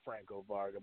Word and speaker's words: Franco Varga: You said it wrong. It's the biggest wrestler ever, Franco Varga, but you'll get it right next time.